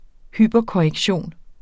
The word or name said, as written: hyperkorrektion